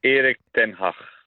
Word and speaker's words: ten Hag: We've been having a bit of discussion in the office about the correct pronunciation of Erik ten Hag. what are you saying? – I would say Erik ten Hag.